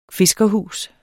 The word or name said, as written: fiskerhus